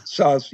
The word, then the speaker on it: Szasz: And so there you're talking about Thomas, is it Szasz? Am I saying his name correctly? – I think Szasz, yes.